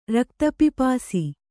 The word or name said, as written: ರಕ್ತಪಿಪಾಸು